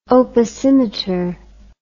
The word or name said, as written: opacimeter